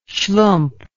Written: shlump

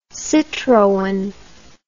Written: citroen

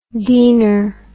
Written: deaner

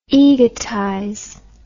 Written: egotize

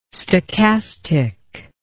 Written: stochastic

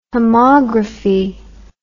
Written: homography

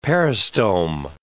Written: peristome

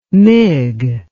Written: mig

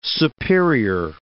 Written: superior

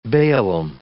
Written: baalim

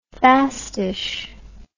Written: fastish

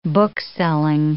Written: bookselling

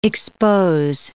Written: expose

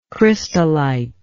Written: crystallite

